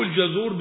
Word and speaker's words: الجزور: المهم قال قوله الجزور بفتح الجيم وضم الزاي.